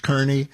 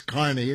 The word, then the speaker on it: Kearny: and you think it's pronounced Kearney. Yeah, it's that place, K-E-A-R-N-Y, but it's Kearney. It's always been Kearney.